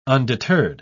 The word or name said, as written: undeterred